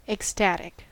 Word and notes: ecstatic: American English

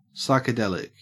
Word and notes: psychedelic: Australian English

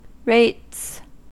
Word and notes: rates: American English

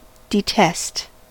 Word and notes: detest: American English

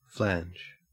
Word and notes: flange: Australian English

